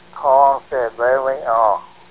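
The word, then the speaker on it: Ponce de León: But I do know that's how you pronounce it. It's Ponce de Leon.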